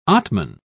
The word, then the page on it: atman: British English